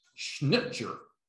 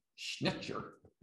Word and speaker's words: Schnittjer: It's Schnitzer, S-C-H-N-I-T-T-J-E-R, Schnitzer. Now, maybe in German it's Schnitzer, not Jer, but maybe it's been Americanized by now in pronunciation. But S-C-H-N-I-T-T-J-E-R. T-T, snip, I think S-J-E-R, I think, Schnitzer.